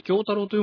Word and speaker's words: 京太郎: からね、一個発見があったんですが、発見してどうするんだって話ではあるんですが、司の声優さんですね、あれは、はい、京太郎と読むんですね。名字なの？三日月とかそういう難しい読み方はしないと。あれは、はい、京太郎さんという風に、ローマ字で書いてあったんで、まあ、これで正しいんですが、まあ、だからね、ほら、前回から言ってるじゃないですか、男性声優の名前がいい加減だと。